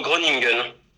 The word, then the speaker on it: Groningen: Euh, alors, on prononce plutôt Groningen. – Groningen, c'est ça Oui, c'est ça.